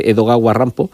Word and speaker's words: Edogawa Rampo: O sea que yo agradezco el que a los fans de Edogawa Rampo les llegue esto, como siempre digo, es el sobrenombre de este personaje, porque como él admira a Edgar Allan Poe, es como un japonés pronunciaría...